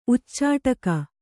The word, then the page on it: ಉಚ್ಚಟಿಗೆ: uccaṭige